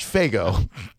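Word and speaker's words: Faygo: I believe that's pronounced Fago.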